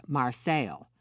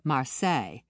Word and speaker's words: Marseille: The local pronunciation was Marseille, not the French Marseille, to start his own label.